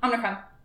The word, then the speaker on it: Omicron: He's like, so how do you say it? Omicron? She's like, Omicron.